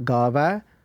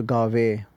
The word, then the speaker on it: Gaavai: And again, trying to keep focused on the actual pronunciation as well. There are many people that when we sometimes read this bani, read Guru Nanak Dev Ji's Japaji in a rush, it can turn into Gave, which is then a lav.